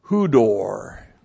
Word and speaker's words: hudor: So pneuma, God the Holy Spirit, and then we have kai ta hudor. Looks like that in the Greek. There's the conjunction chi, and then we have ta, the definite article, and then H-U-D, long O-R, hudor.